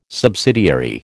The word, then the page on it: subsidiary: American English